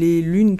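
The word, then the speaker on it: L'un·es: Et donc, ça s'écrit en fait L'UN.ES. Donc voilà, on peut dire les lunes. Moi, généralement, j'utilise juste ce terme-là. Mais c'est vrai qu'on veut être complet, ben, on dit les lins, les lunes.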